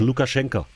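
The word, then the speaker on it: Lukaschenka (Лукашэнка): Also du musst es, du musst es wie so eine Mischung aus O und A sprechen, so. Lukaschenka. – so, so, so wird es ausgesprochen.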